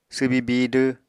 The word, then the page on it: swbi bidw: apatani